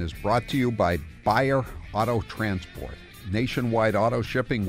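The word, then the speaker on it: Baier: That's B-A-I-E-R. B-A-I-E-R, pronounced Bayer.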